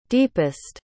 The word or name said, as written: deepest